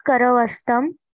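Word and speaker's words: करवस्त्रम्: Etat karavastam. Did I pronounce it correctly? – Yeah.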